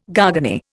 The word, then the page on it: garganey: Indian